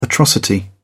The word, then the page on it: atrocity: British English